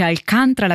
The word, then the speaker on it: Alcântara: E questa era la voce della cantante portoghese Carminho Marcha de Alcântara, così si pronuncia Alcaranta, che io l'avrei letto così, invece Alcântara l'abbiamo sentito cantato da Carminho, che ci ha cantato una marcia popolare che fa palpitare il mio cuore, un brano tratto da Portuguesa, un album del 2023.